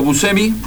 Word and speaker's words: Buscemi: Y los vocales titulares, Sergio Buscemi, lo dije bien. – Así es.